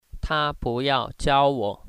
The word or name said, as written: iao